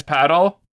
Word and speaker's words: paddle: And he told a very dramatic story that took place on the, I may be saying this wrong, but they were playing paddle tennis as they like to do. – I think it's paddle, yeah. – It's paddle? It's not padel? – It's paddle.